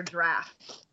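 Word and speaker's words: Draught: Also, I am going to point out that because I'm an English whore, episode 2 is actually called A Bitter Draft. – Is it? – Ooh. – Pronounced draft. Yeah, I looked it up in the actual like text that's actually how interesting.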